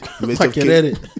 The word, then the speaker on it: Makerere: If you are based in somewhere else, you go to Makerere University or whatever it's called. – Makerere. Sounds like Makelele.